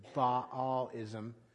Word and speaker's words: Baalism: If you wanted to be fussy about it, you would probably pronounce it Baalism.